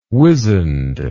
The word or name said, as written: wizened